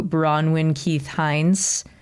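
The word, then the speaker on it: Bronwyn Keith-Hynes: And we'll give shout outs to Bronwyn Keith Hines. Let me know if I'm mispronouncing anyone's name. – Correct.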